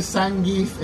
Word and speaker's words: Zangief: Bowser, Zangief, Robotnik, Bison. – Qué buena pronunciación de Zangief, güey.